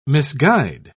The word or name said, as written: misguide